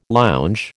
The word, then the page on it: lounge: American English